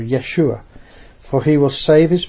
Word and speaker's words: Yeshua: Now, I should mention, the word Yeshua, we get the word Jesus, is just the Greek version of Yeshua. And we in English pronounce it Jesus. If you're in France, you pronounce it Yesu. But in Hebrew, the name is Yeshua.